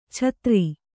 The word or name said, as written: ಚತ್ತರಿ